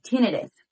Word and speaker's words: tinnitus: tinnitus. Tinnitus is also the correct pronunciation.